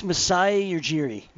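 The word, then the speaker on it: Masai Ujiri: How do you pronounce it correctly? Missouri? – It's Messiah Ujiri.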